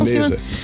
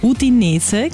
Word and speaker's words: Udinese: Er spielt dort für den Verein Udines Calcio, oder habe ich das richtig ausgesprochen? – Udinese. – Udinese Calcio, gut, er ist jetzt dort offiziell präsentiert worden.